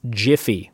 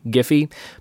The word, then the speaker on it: Giphy: That is Giphy, or as the creator says, it's pronounced Jiffy, which I think is dumb. But hey, there you go. Graphics interchange format, starts with a G, hard G, Giphy, but he says it's Jiffy.